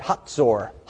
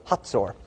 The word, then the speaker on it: Hazor: You could pronounce it Hazor. But when my archaeologist professor took me through Israel, he always pronounced it Hazor. Hazor.